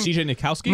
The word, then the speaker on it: Nikowski: How do you pronounce it? Nikitsky? What is his name? – CJ Nikowski? – Nikowski, yeah.